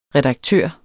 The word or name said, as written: redaktør